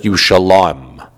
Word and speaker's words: Yerushalayim: So that makes it be pronounced usually. That means it's pronounced Yerushalayim.